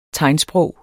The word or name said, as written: tegnsprog